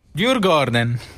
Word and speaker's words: Djurgården: Djurgården.